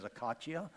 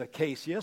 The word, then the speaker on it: acacia: You've been pronouncing this word as acacia. Maybe you're adding too much international flair, but it's acacia."